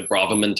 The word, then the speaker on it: Braverman: So anybody who's ever done like the Braverman test before, it's like Brave R-Man, like that's how you would spell it, Brave R-Man test, talks about your, like it talks about four neurotransmitters, dopamine, serotonin, acetylcholine, and GABA.